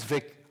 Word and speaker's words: Vic: I'm going to go from W to V, because Canadians read V and they say V. And so it's easy for me to say, my name's Vic.